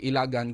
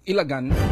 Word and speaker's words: Ilagan: Mag-pronounce kila sina ko, no? Ilagan, no? From the word ilag, no? But silingon likaw, mga kasama, hindi Ilagan, kundi Ilagan.